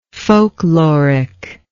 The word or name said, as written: folklorically